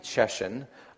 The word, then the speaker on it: Cieszyn: C-I-E-S-Z-Y-N. How do you pronounce it? I heard her say something like Czeszyn into the phone, and that was enough to confirm that I had the right place.